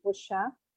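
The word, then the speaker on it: Rorschach: Então, fui trabalhar com o método de Rochat, é, a pronúncia do meu professor, que eu carrego até hoje,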